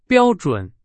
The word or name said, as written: 标准